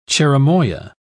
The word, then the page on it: cherimoya: British English